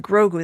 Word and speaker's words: Grogu: So let's play a little clip from The Mandalorian and Grogu.